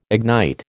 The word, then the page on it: ignite: American English